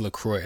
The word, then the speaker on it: LaCroix: And they said, yeah, it's pronounced LaCroix. And then they spelled it like C-R-O-Y is how it's pronounced.